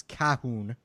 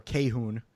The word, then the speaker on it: Kahun: Uh, there was a report, uh, some German publications reported that German Olympic forward Dominik Kuh- or it's Cahoon or Cahun. Uh, it's spelled K A H U N.